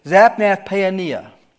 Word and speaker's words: Zaphnath-Paaneah: It is Zaphnath-Paaneah. Zaphnath-Paaneah is a name that Pharaoh gave him. I'd want to give that one back, I think, but that's what he gave him. Zaphnath-Paaneah.